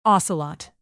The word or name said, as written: ocelot